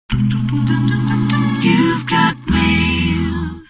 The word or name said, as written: You've Got Mail